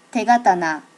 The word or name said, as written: tegatana